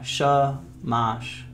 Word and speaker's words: shamash: What is this, shmash? Yeah, shamash.